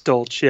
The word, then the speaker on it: Dolch: It's Dolch, yes.